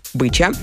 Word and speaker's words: Быча: Его называли шутливо по фамилии Быча, ударение на «ы», Быча. Я так и привыкла, и вот сейчас нам уже по 37, а он у меня до сих пор Быченька, Быченька наш любимый.